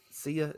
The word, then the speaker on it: Sia: I mean, obviously they, you know, I mean, for Sia, is that how it's pronounced? – Yes.